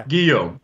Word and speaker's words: Guillaume: And I just said it wrong. I said it's Guillaume. – Guitar, yeah. Guillaume. That's the way to remember. – I had it in my head that it was a gue for some reason, but that's just... – Well, the G-U... Well, actually, the word guitar in English is one of the very few where G-U is actually pronounced gue, right?